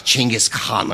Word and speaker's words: Genghis: And he broke it down and gave me the syllable by syllable. It's like Genghis Khan or whatever. – Oh, he said it's more complicated. – But like, Ching is in like, you know, ka-ching.